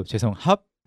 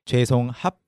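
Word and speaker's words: 죄송합니다: So when I say up to 죄송합, I don't open my mouth again to say 죄송합. I don't say that.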